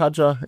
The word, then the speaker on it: Hadjar: Tja, meine Punkte für die beiden Kameraden lauten 4 für Liam Lawson, 6 für Isaac Hadjar. Ich bleib bei der englischen Aussprache, die habe ich einfach besser im Ohr. Aber ja. Da ist beides korrekt, er ist mit beidem fein, hat er gesagt.